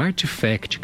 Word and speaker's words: RTFKT: E ainda de bastidores do mercado, a Nike comprou a Artifact, que se escreve RTFKT, que é um estúdio de NFTs.